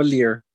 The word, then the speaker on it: Lear: La pronunciación Inglés como Lear.